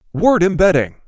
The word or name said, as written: word embedding